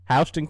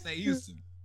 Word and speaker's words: Houston: Houston County. – My mama fixed me real quick. I said, that's Houston. Son, that is Houston. You better say it right.